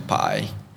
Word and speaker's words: Pai: I went to Pai. – Pai? – Yeah, Pai is a city just a bit north. – The life of Pai? – Yeah, that Pai, like it's pronounced that way.